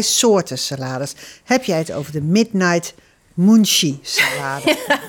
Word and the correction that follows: munchies: Moet je het zo uitspreken? Munchies. – Munchie.